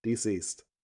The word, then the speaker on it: DCeased: So that's spelled DCEASED Collected. Deceased.